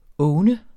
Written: owne

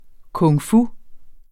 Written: kung-fu

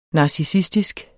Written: NARCISSISTISK

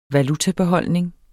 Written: valutabeholdning

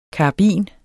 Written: karabin

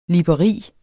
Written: LIBERIERNE

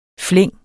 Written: flæng

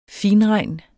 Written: finregn

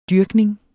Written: dyrkning

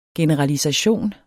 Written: generalisation

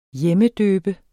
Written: hjemmedøbe

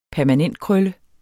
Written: permanentkrølle

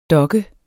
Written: dokke

